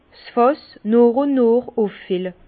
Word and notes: 's Fàss nooch ùn nooch uffille: Bas-Rhin; Herrlisheim

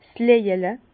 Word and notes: 's Löjele: Bas-Rhin; Herrlisheim

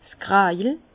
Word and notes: 's Kräjel: Bas-Rhin; Herrlisheim

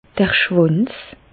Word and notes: d'r Schwànz: Bas-Rhin; Reichshoffen

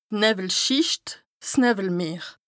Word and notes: d' Nëwwelschìcht, 's Nëwwelmeer: Bas-Rhin; Strasbourg

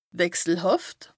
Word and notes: wechselhàft: Bas Rhin; Strasbourg

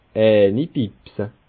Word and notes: eini bibse: Bas Rhin; Schiltigheim

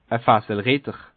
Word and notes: e Fasselritter: Bas Rhin; Schiltigheim

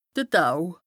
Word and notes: de Tau: Bas Rhin; Strasbourg

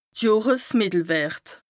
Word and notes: de Johresmìttelwërt: Bas Rhin

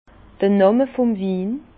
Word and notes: d'r Nàmme vum Win: Bas-Rhin; Herrlisheim

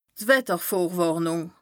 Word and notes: d' Wettervorwàrnùng: Bas-Rhin; Strasbourg

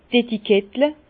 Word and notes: d' Etikettle: Bas Rhin; Herrlisheim

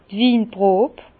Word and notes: d' Winprob: Bas-Rhin; Herrlisheim

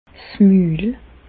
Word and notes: 's Mül: Bas Rhin; Reichshoffen

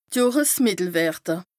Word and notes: Johresmìttelwërte: Bas Rhin

